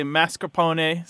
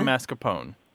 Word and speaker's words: mascarpone: Some people say mascarpone. Some people say mascarpone.